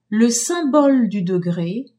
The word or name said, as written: symbole du degré